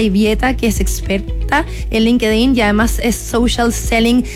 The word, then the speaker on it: LinkedIn: y eso era esta tremenda red social que es LinkedIn, otros la pronuncian distinto como LinkedIn, así,